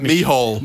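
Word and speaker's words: Micheál: Because the UK Prime Minister Andy Burnham and Irish Taoiseach Michelle Martin, definitely that's how I pronounce it, right? – Michal. – Oh, my God. – Not Michelle.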